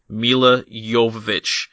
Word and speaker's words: Jovovich: And for those of you listening at home, that is the correct way to say her name. Milla Jovovich. I heard her say it. I listened to the director's commentary on this and she was on it.